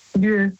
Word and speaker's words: lieu: Lü. – Lü? – Also Lü, das schreibt man also L-I, E und dann U.